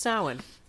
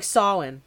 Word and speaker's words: Samhain: So, I looked it up and it's pronounced Samhain. Yes. Or like Samhain.